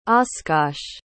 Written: oscosh

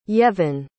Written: yevhen

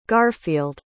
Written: garfield

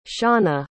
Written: shaunna

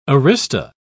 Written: arista

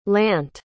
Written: lant

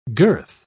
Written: girth

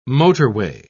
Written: motorway

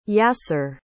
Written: Yasser

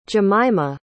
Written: Jemimah

